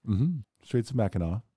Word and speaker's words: Mackinac: Anyway, like just before we started, I'm sitting here, like 2 minutes before we started, I found out that it's spelled Mackinac and it's Mackinac. I absolutely would have recorded this entire episode. – Mackinac.